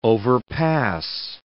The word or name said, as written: overpass